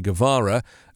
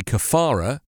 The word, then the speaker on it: Guevara: DeSantis, mispronouncing the name of the revolutionary leader Che Guevara as Che Kafara, railed against students who wear T-shirts he said were oblivious about what communism represented.